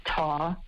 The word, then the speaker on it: tau: In mice, extra virgin olive oil can flush out, I think you pronounce this TAU, T-A-U, the Greek letter, a protein that hinders language skills and memory in humans.